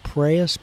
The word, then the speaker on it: praus: And so there's this Greek word, and I'm probably going to mispronounce it, praus, P-R-A-U-S, is how it's spelled.